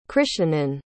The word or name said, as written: krischnan